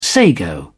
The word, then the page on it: sago: British English